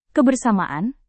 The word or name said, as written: kebersamaan